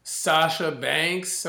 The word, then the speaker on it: Sasha Banks: So I'm kind of between Sasha Banks.